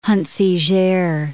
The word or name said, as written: Huntziger